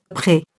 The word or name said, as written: pré-